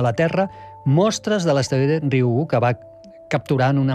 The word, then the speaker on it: Ryugu: Ryugu. És molt fàcil, com una I, Ryugu.